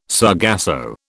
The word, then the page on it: sargasso: British English